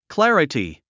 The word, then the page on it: clarity: klǽrəti; クラリティ